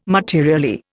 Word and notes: materially: Indian

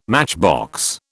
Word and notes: matchbox: British English

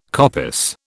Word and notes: coppice: British English

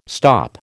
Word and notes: stop: American English

